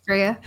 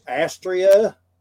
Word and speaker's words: Astrea: Yeah, my name gets butchered a lot, but it's Greek, and that's why. – Born in Georgia, I will butcher it. – That's actually Astrea. – Yeah, accent on the second syllable.